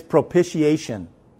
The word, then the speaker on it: propitiation: I've never known how to pronounce it correctly, so I finally looked it up in a dictionary on my phone, and I hit the play button to where it says to how to pronounce it. It's propitiation.